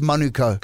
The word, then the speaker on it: Manukau: out in in in in, uh, in Manukau, it's it's Manuko. Co. It's not that hard, yeah.